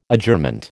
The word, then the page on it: Adjournment: American English